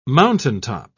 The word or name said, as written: mountaintop